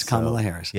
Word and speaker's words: Kamala Harris: It's Kamala Harris.